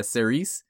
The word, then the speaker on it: Cerise: and it's so I'm pretty sure it's pronounced Cerise. – Yeah, that's what I was going to say, too.